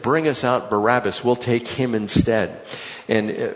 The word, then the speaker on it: Barabbas: With Barabbas, remember I told you that Barabbas is not the proper way to say his name.